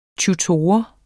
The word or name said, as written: tutor